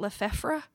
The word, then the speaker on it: Lefebvre: I mean, I do speak French a little bit, but in my mind, it's Lefebvre. But I think in French, they pronounce it Lefebvre. So I don't. – I'm literally going like Brett Favre. And so I'm going Favre.